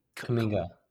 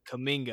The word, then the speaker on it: Kuminga: Kuminga. – Kuminga, yeah.